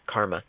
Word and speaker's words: CCRMA: So the places that one of the best places that I've found is called Planet Karma, which is spelled Planet CCRMA, but pronounced Karma.